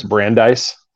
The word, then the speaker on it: Brandeis: I want to say it's Brandeis.